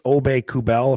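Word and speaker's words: Aubé-Kubel: They got that Nicholas Obey-Kubel, if I'm pronouncing his name correctly. – That's right. Yep.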